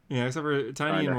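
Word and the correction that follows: Tynion: wasn't that big because he was just focusing on Batman and then Nice House and Joker. – Tynan. The correct pronunciation is Tynan.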